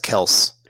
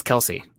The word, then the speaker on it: Kelce: So the E is silent on Travis Kelce. – But his brother says it's Kelce. – Okay. Yeah. His own teammates say he's never told us that it wasn't Kelce.